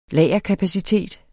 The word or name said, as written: lagerkapacitet